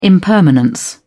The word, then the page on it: impermanent: British English